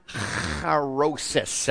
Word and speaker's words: charoses: So it's pronounced charosis. Everybody say it. Charos. That's really good. You guys got the cha in there.